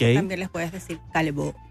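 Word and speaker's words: Callebaut: Y ella trabaja con una marca que se escribe Calle Baut. Pero es una marca francesa que se dice Calebo.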